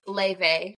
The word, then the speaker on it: Laufey: And her name's Leve. But it's spelled Laufey. So it looks like Leife, but it's Leve.